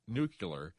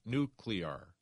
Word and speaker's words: nuclear: She says, please tell me that it's not okay to say the word nuclear. It should be, of course, nuclear. And that's a very common mistake.